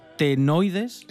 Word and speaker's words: carotenoides: La nueva especie dicen que tiene potencial de uso biotecnológico por su capacidad de producción de care, a ver si lo digo bien, caret carotenoides. Exactamente. Carotenoides, sí.